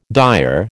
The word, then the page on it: dire: American English